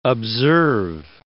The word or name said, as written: observe